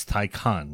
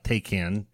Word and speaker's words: Taycan: And he said Manti, just like it's Taycan, not Taycan.